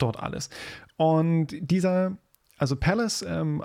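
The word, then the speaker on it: Pvlace: Es gibt in Heilbronn ein, ähm, ein Dude, der, der nennt sich halt Palace, also PV Lace, Palace ähm, ausgesprochen,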